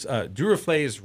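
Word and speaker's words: Duruflé: So of course, Laura, you chose Du Reflé's Requiem. – Yes. – How am I doing on the pronunciation? – Pretty darn good.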